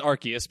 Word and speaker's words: Arceus: Well, it is Arceus because they said it in the trailer.